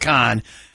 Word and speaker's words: Cannes: It's Cannes. Okay, anyway. So it's all that's where the new fashion trends are suddenly, you know, out. If you do it at Cannes, what do you think is the newest fashion trend? – Hike up your boobs.